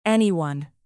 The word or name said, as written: anyone